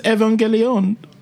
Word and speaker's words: Evangelion: Why did you sound like a dirty wop when you said Evangelion? – That's how it's pronounced. That's how it's pronounced. I thought these were Nuketard shows that you're watching. – It's pronounced Neon Genesis Evangelion.